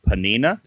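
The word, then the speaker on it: Peninnah: He had one wife named Hannah, who had no children, and I'm going to butcher this name, Panina. Does that sound right? – Yeah, that's what I came up with in my head.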